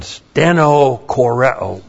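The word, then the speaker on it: stenochoreo: And then stenokoreo, great word. Phlebo was a great word. Now, stenokoreo. S-T-E-N-O-C-H. Long O-R-E. Long O. Stenokoreo.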